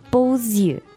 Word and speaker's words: beaux yeux: Beau, but because eyes is plural, we say beaux yeux. – Yeah, that's the liaison. – Because normally the X at the end of beau is silent, but here you have to pronounce it.